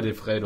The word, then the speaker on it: De Vrij: Ich tippe mal De Vrij. Bleib mir bei De Vrij, du hast das schon richtig gesagt.